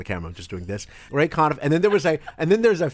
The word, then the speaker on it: Conniff: Of course, they pronounced it Ray Conniff, but it's Ray Conniff, and it's all singing Christmas songs.